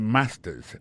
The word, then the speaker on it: master's: Accent tonique, là, I got a master's. Master's, là, c'est mot que les maîtrises, là. Regardez bien pour voir que l'accent tombe sur le premier syllabe, là.